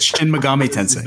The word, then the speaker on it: Shin Megami Tensei: Oh God, I'm gonna butcher this. Shin Meguro Tensei Five is that, Shin Megami Tensei.